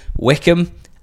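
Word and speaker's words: Wycombe: We'll be at Wickham this weekend for that game between, and I'm going to say it correctly, Wickham and Lincoln.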